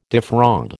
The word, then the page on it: defraud: American English